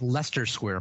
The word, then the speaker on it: Leicester Square: I don't know if I'm saying that right. That's Leicester Square, my friend.